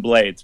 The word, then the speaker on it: Blades: Ruben, is it Blades or Blades? – I've heard it pronounced both ways. I pronounce it Ruben Blades.